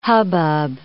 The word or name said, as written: hubbub